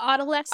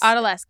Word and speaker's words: Odalisque: Oh, is that how you say it? – Odalisque. – Odalisque. Thank you.